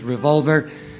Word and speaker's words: Revolver: Extraordinario el álbum Revolver, así se pronuncia, yo sé inglés, yo sé lo que les digo, la pronunciación es Revolver y Robert Soul el anterior.